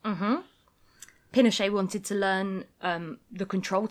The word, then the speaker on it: Pinochet: Yep, the government is overthrown by General Pinochet.